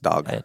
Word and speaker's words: dag: With an A sound, which I guess if you spelled it D-A-G in English, you would say, want to say dag. That's not. – It's pronounced dog.